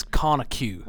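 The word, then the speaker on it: Conecuh: And I think their pronunciation of it is Conicue.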